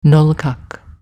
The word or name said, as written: Nolkak